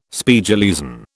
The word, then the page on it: Spiegeleisen: British English